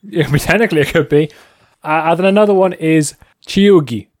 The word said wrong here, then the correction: cheugy: I'm probably butchering that word, but it doesn't look right. C-H-E-U-G-Y. Chugi. Chugi. That's what it is.